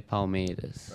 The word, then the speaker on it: Palmeiras: Oh, Palmeiras.